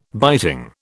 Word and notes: biting: British English

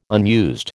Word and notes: unused: American English

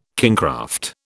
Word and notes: kingcraft: British English

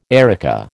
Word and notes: erica: American English

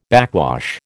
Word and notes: backwash: American English

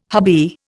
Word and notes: hubby: Indian

